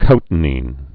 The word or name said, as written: cotinine